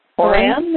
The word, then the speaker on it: Horan: Does anyone know how to pronounce his last name? H-O-R-A-N? – Horan, maybe? – Horan? – Yep. – Horan? – Yep. – Horan or Horan.